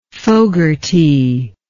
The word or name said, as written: Fogarty